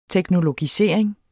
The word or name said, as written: teknologisering